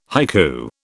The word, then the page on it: haiku: British English